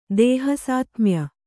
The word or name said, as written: ದೇಹಸಾತ್ಮ್ಯ